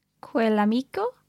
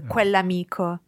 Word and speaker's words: quell'amico: Quell'amico. – Bravissima. I remember double L apostrophe and it sounds like one word. Yeah. Quell'amico. – You run that together perfectly. Quell'amico, quell'amico.